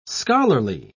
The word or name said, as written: scholarly